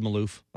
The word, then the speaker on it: Maloof: Well, that same recipe came to a head when Maloof, am I saying that right? – I think so. – Maloof? – I've heard Maloof. – Okay, we'll go with it. We'll roll with that.